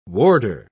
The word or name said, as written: warder